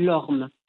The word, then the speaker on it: horme: quelque chose qu'on appelle l'orme, qui est une force qui le pousse au travail. L'orme, ça s'écrit H-O-R-M-E.